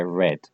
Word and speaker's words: read: No se cambia cómo se escribe, pero se dice I read.